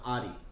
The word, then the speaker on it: Aris: Even though it's spelled A-R-I-S, in Greece it's pronounced Adi. So Adi. My dad talks to me, he calls me Adi. So Adi. Close friends.